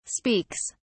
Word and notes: speaks: British English

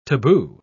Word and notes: tabu: British English

